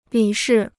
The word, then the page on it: 理事: lǐ shì